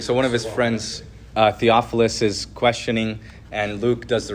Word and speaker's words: Theophilus: Theophilus, I said it right.